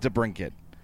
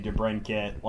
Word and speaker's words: DeBrincat: We also have clarification on the other name we can't pronounce. It is DeBrincat. – Yeah, that's what I was told that as well, and I've been trying to say DeBrincat,